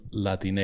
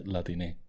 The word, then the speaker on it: Latine: Yeah, this is like Latin and then ending with an E, pronounced like Latine. Instead of Latino or Latina, it's Latine.